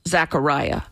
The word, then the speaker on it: Zachariah: Zachariah. I was wondering how to pronounce that. – Well, they call it Zachariah, but it's spelled the same as Fareed Zakaria.